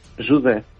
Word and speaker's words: José: Pero José en portugués, José. – Jude. – ¿Cómo? – Jude. – Pues como Jude, como dice Jude.